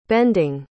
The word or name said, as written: bending